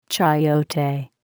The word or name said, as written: chayote